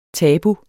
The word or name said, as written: tabu